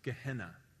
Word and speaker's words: Gehenna: There's a word in the Bible that means a place of eternal punishment for the wicked dead, and it's a word pronounced Gehenna.